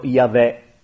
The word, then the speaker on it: Yahweh: Si pronuncierebbero Yahweh, ma non si devono pronunciare.